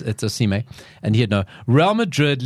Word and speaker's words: Osimhen: I found it his Osimhen. That's how you say it.